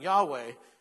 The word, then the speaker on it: Yahweh: So the pronunciation Yahweh is our best estimate of how Yahweh could be pronounced. Since we do not have the original vowels, we don't know for sure.